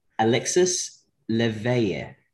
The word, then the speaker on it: Alexis Léveillé: Alexis Léveillé. – That's exactly it, actually. Yeah, that's pretty good.